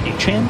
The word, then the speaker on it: Etienne: Well, Travis Etienne, the Saints running back, the other day said that his last name is actually pronounced Achane.